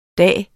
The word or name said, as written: DAGE